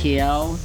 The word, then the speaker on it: Keough: Oh my God, I am obsessively stalking Shane Koff. You know, Gina Koff's son. – Shane Keo. – Oh, is that how you say it? Keo? – It's okay.